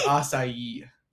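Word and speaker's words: açaí: That was me with acai. – That's how you pronounce it. – I know, but I called it a key for like the longest time.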